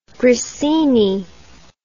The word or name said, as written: grissini